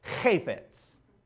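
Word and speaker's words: Hafetz (חפץ): This word here, delight, is the Hebrew word hafetz. Now let me say it properly. Hafetz.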